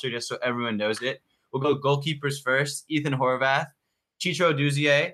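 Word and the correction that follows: Chituru Odunze: Is that pronounced correctly? – That was not close. – Go ahead for me. – Chituru Odonze. – Okay. Chituru Odonze. Thank you very much.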